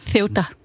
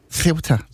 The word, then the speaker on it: Ceuta: Kan vi lige få den helt præcis udtale på plads? – Ceuta. – Ceuta. Ja, det er... – Det er med... Ja, vi går med den danske Ceuta.